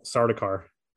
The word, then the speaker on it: Sardaukar: Oh, Sardaukar.